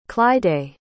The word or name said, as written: clydai